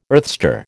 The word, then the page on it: earthstar: American English